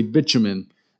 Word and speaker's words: bitumen: Tar probably actually was naturally occurring, if I can say this correctly, bitumen, B-I-T-U-M-E-N. I looked up the pronunciation, so I think that's the right pronunciation for it, which is basically a naturally occurring asphalt, which makes a really great mortar.